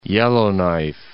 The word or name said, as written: Yellowknife